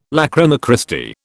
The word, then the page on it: lachryma Christi: British English